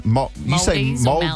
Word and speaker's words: Maldives: Maldives? Is that how you say it? – I think it is Maldives.